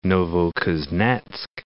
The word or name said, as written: Novokuznetsk